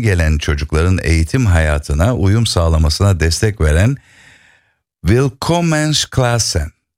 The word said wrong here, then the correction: Willkommensklassen: Tam telaffuzunu söyleyeyim bana. – Willkommensklassen. – Willkommensklassen, evet. Benimki olmamış, evet.